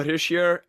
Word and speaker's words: Hischier: How do you say it? Nico Hischier? Is that how you say it? Hischier? – Yeah, it's Nico Hischier and Jesper Brad.